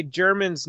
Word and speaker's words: German: Apparently, Alexei German's name is pronounced German. Have I been saying German the whole time? – It's German.